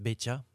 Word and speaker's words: Beca: Saya mau tamasya keliling-keliling kota, hendak melihat-lihat keramaian Yeah, that was Hai Becha, B-E-C-A, pronounced Becha.